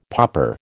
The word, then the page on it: popper: American English